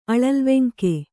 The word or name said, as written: ಅಳಲ್ವೆಂಕೆ